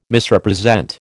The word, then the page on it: Misrepresent: American English